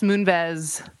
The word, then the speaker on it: Moonves: And I, you know, Les Moonves, is that how you pronounce his last name? – Yes.